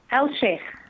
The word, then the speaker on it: AlSheikh: AlSheikh.